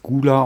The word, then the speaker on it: Gvla: Da habe ich das Problem, dass ich, also ich glaube, dass das Gula ausgesprochen wird. – Ja. Ähm, die schreiben sich allerdings G-V-L-A. Äh, ich komme darauf, dass es Gula heißt, weil ein Song Surrender heißt. Und da wird es auch das U durch ein V ausgetauscht.